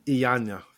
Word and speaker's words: Iyanla: Her whole comment and her retort back to people who questioned and really derided her about her whole bus driver, bus conversation, Ivana. – Iyanla.